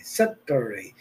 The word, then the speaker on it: Saturday: Se escribe Saturday, así como se oye. Pero ya ven que en el inglés un poquito raros, ¿verdad? Son los ingleses. Saturday. La T como que sopla, saca.